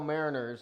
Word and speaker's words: Mariners: In the old Seattle Mariners, didn't they have like a boat? – They had a boat.